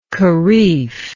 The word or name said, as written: kharif